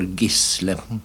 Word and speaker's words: Gisle: Jo, jeg vokste opp med å bli kalt for Gisle. Så for meg er det naturlig å høre det navnet sagt, sagt slik.